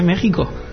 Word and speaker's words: México: Se escribe en México, pues se dice México. – Bueno, yo leo como veo. – Tú como un ordenador, ¿no? Ahí pone X y hay que decir X, ¿no? – Nos escribe Gustavo Antonio Alvarado Contreras.